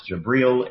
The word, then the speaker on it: Jibril: Now, contrast this with Islam, where Muhammad had visions, and he says it was the angel Gabriel, pronounced Jibril in Arabic, but he was horrified.